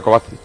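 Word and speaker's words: Kovačić: Y está metiendo a Kovacevic. A Kovacic. – Otro que le llama a Kovacevic. – Sí. Me sobra una sílaba.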